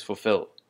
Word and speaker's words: fulfill: And so the next word is fulfill. Fulfill. And once again, the emphasis is on the second syllable.